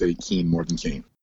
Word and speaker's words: Kean: So it's off more often than not do people say Keene, and it's Kane, K-A-N-E, the pronunciation, but it's spelled K-E-A-N.